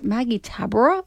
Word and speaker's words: Maggie Tabora: Like I've got a, is it Maggie Tabora? Is that how you say it? – Yeah, Maggie T.